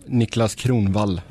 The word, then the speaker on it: Niklas Kronvall: Nicholas Cronwall retiring. – Yep. – How do you say his name in Swedish? – Niklas Kronvall. – Yeah, see, that's not how I said it.